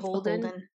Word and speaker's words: BHLDN: It is part of the Urban, or URBN, that's how you'll see it, brand, which includes Urban Outfitters, Free People, how do you say this? Baldit? – Beholden, I think. Beholden.